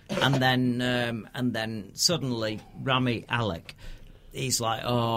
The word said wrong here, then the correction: Rami Malek: I don't think that's how he pronounces it. – Rami Malek. – That's it.